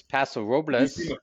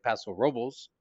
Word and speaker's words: Paso Robles: there's Paso Robles. R-O-B-L-E-S. The locals say Paso Robles, which is a real cringe to the tongue.